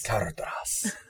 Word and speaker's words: Caradhras: Caradhras. – Yes. You must pronounce every letter.